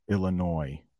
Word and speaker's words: Illinois: I know it ends with an S, but it's pronounced Illinois. Now, there are some native English speakers who will pronounce that Illinois, but it's not.